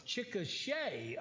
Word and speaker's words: Chickasha: Uh, I am from the thriving metropolis of Chickasha, Oklahoma, is how we pronounce it. There's there's no Shaw in it,